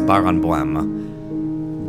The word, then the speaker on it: Barenboim: I'm guessing it's pronounced Baron Boim. Barenboim.